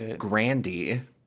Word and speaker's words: Grande: I think it's Grande. I think it's just like the Spanish word. – It's not, apparently. – What is it? It's Grandy. Unless they're playing a joke on me. Grandy.